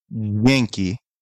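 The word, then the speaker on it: Dźwięki: So they called me to correct me on how I say this word, Vieki, which they correct me. It's called Yankee, which it sounds like there's an N in it, but it's spelled D-Z-W-I-E-K-I.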